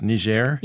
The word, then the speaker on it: Niger: I think it's often pronounced Niger. – Yes. Niger Republic.